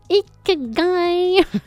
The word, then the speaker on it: ikigai: L'ikigai, alors Keizako, eh bien c'est un mot japonais. C'est même un concept japonais qui se prononce ikigai.